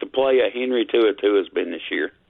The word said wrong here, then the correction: To'o To'o: Well, I don't think you said his name right, and I don't usually say it right, but I believe it's To'o To'o.